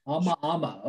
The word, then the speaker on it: Amaama: So, did I pronounce it right? How do you pronounce it? – Amaama. – Amaama. Okay. So I was wrong.